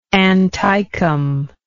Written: anticum

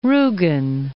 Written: Rugen

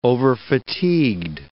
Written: overfatigued

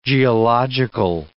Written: geological

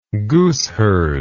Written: gooseherd

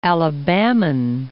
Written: Alabaman

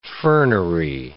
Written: fernery

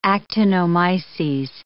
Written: actinomyces

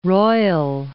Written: Port Royal

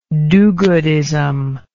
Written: do goodism

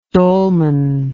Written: dolman

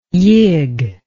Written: yig

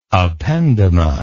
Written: ependyma

